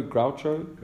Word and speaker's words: Groucho: So an example of this is like, how do you pronounce his name? G-R-O-U-C-H-O, Groucho? – Groucho Marx.